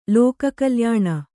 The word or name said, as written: ಲೋಕಕಲ್ಯಾಣ